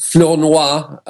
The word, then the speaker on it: Flournoy: And in effect, she was appointing Michel Angélique Flournoy, I pronounce it in the French way,